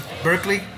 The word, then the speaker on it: Berkeley: No, Berkeley es en Boston. – Ah, okay. – Son 2, se pronuncia igual pero se escribe diferente. – Ah, okay. – Berkeley, California, es cerca de San Francisco.